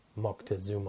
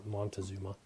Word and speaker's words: Moctezuma: a guy by the name of Moctezuma or Montezuma, as they say here in the states, but it's Moctezuma is the appropriate pronunciation.